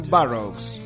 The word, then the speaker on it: Burroughs: bueno, hoy vamos a hablar de un autor que se llama William Burroughs.